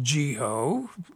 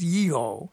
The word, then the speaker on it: Yeho: Wasn't pronounced Jeho. They didn't have a J in Hebrew. It's Yeho.